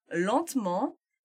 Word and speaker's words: lentement: Se forem pronunciadas devagar, você ouve todos os us. Us? É, a gente pode falar us, né? – Sim, tá? sim. – Tá? – Já falando depressa, viram lentement e rapidement.